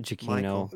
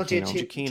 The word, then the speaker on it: Giacchino: Giacchino. – Michael Giacchino. Giacchino.